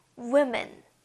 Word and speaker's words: women: 그래서 여자는 복수일 때 발음을 조심하셔야 되는데요. women, women, woman, women 엄청 달라지죠.